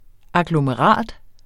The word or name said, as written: agglomerat